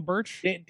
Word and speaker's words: Burch: Daniel Burchill. Is that the right name? Am I adding some syllables there? – I don't know. – Daniel Burch. – Daniel Burch. – Danny Burch. Thank you.